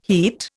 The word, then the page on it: heat: Indian